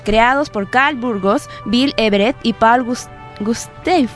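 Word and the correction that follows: Gustavson: No sé pronunciar esto. – Paul Gustafsson. – Gustafsson será, ¿no?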